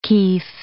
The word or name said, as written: kef